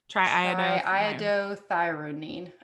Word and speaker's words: triiodothyronine: And then T3, which is, I'm going to butcher this, but I'm going to try it anyway, is triiodothyronine.